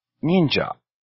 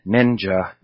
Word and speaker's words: ninja: Ninja, a very shallow I sound. Again, anglicizing it, but in reality, the I in Japanese always sounds the same. In the language, it does not deviate.